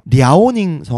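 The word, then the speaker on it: 랴오닝 (辽宁): 랴오닝 성이 있고요. – 랴오닝 성은 동북 삼성 해 가지고 맞습니다. 동북 삼성이 예, 이쪽 동북 지역입니다. 랴오닝 성은 한국어 발음은 요녕성입니다.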